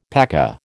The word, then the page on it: paca: American English